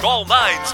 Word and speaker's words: 999999999: Before that, we had the band whose name looks like 999999999, but is in fact pronounced All Nines.